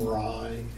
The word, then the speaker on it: Rye: Jim Rye, and it's pronounced Rye, like Rye Bread, Rye Whiskey.